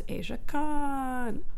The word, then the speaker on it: Aja Kong: And she is a Japanese professional wrestler, better known as Aja Kong.